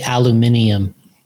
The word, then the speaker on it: aluminium: So he wants us to say it the way you said it, but it's he wants us to say aluminium. – Oh, okay. Why? – That's the way it's said in Europe. – Oh, is it? – Yeah. – How do you say it? – Aluminium. – Okay. Interesting. Aluminum's the correct way, though.